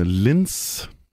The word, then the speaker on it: Linz: Fra en øh, by, der hedder øh, Linz. Udtaler jeg det korrekt? Ja, det er rigtigt, ja.